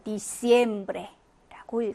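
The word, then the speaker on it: diciembre: 에를 강하게 해서 디셈브레라고 읽어주시면 됩니다.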